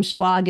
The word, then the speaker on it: Schlag: My last name, Schlag, is spelled S as in Sam, Chlag.